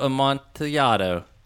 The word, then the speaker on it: Amontillado: It's the cask of Amontillado.